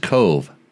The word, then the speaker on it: Kove: Who is a Cove, and I hope I'm saying this right, factory rider, or is just Cove. – Cove is correct, yeah. That's how they say it.